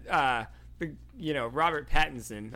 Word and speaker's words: Pattinson: There's a scene that, Robert Pattinson, I actually said it right this time, did in the Twilight movie that came out, like, a year before this.